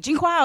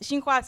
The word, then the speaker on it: Xinhua: First of all, you know, they're like, the pronunciation is different, like Jinghua. I was like, well, it's Xinhua, so debate pronunciation.